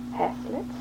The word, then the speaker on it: haslet: How was it pronounced? – Haslets.